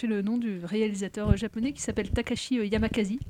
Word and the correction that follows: Yamazaki: Ah, Yamakaki ah, Yamazaki. Oh là je vais arriver. – C'est le festival. – Festival de la faute. Takashi Yamazaki.